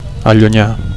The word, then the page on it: allunyar: Catalan